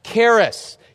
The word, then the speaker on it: charis: Now, the Greek word for grace, that's the word charis. You would spell it in English, C-H-A-R-I-S.